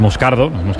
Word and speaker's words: Moscardo: Entonces, sigo, como bien ha pronunciado Unai, es Moscardo, brasileño.